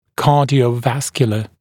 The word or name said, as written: cardiovascular